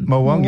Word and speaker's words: morwong: Blackfish, Taylor, Yellowtail, Blue Mackerel, Leather Jackets, Snapper, Morwong. Have I said that right? – Yep, Morwong. Yep.